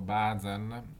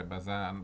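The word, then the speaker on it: Buzan: Bazan, anzi si dovrebbe pronunciare Bazan,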